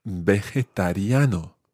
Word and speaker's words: vegetariano: It is quite tricky to say. Try that syllable by syllable. Ve. – Ve. – Je. – Je. – Ta. – Ta. – Riano. – Riano. – Vegetariano. – Vegetariano.